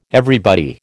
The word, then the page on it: everybody: American English